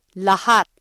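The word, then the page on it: lahat: Filipino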